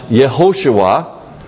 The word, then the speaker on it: Yehoshua: Because in Greek, Jesus, which is a translation of the, or more of a transliteration of the Hebrew word Yeshua, or before the Babylonian captivity, pronounced Yehoshua, it's important to understand that that is the same as the word that's translated in our Bibles, Joshua.